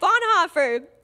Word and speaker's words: Bonhoeffer: How do you say it? That. What they just said. Bonhoeffer.